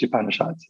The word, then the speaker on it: Upanishads: One of the earliest Upanishads.